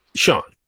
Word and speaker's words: Sean: Sean. – Is it Sean? – Sean. – How do you spell it? – S-E-A-N.